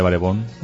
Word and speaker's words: Barebone: Bienvenidos todos y sobre todo antes de empezar este espacio me gustaría ponernos de acuerdo en la pronunciación. ¿Lo llamaremos Barebones todos o seré el único que haga la pena? – No, vamos a llamarle Barebón. – ¿Barebón? – Barebón. – Barebón o Barebone.